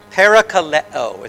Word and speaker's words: parakaleo: Paraclete is parakaleo is how you pronounce it in Greek.